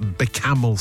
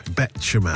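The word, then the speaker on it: béchamel: My mother-in-law, Christine, always calls it Becamel sauce instead of Bechamel.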